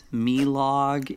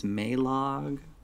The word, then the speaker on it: Melog: Something I would like to bring up, just as a, you know, casual fan of the original show, I do not believe it is pronounced Milog. It is Maylog. Just saying, in the original show. So I just think they read it and it's spelled M-E-L-O-G. So they said Milog and it's Maylog.